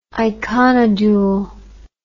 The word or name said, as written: iconodule